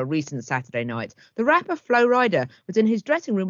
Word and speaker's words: Flo Rida: Flo Rida, whose stage name honours his home state but is pronounced like Flo Rider, is fastidious about his physique.